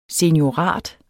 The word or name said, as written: SENIORAT